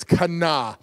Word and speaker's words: kana: And when you pronounce it, the N has a longer, you linger a little longer on the N. It's kana.